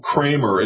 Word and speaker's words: Kramer: You know, Project Veritas a couple years ago had undercover interviews with a guy named Bob Kramer. It's spelled like cream, but pronounced Kramer.